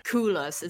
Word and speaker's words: Coolors: Coolors. – It's it's it's spelled C O O L O R S.